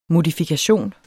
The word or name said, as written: modifikation